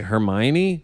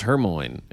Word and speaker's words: Hermione: They were like, Hermione? I was like, that was Hermoine. It was O-I-N-E.